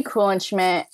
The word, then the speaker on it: Kuhlenschmidt: It's at G Kuhlenschmidt on all three apps.